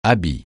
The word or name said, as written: habit